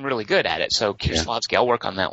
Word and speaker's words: Kieślowski: So Blue, of course, is Kieślowski. I'm not sure. Dingus, you're Polish. How do you say his name? – Kieślowski. – Kieślowski. – Yeah.